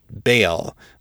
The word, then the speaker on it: Baal: As for the pronunciation, the Canaanite god is most properly called Baal, but I chose to use the pronunciation from Dungeons & Dragons Forgotten Realms, where Baal is the Lord of Murder.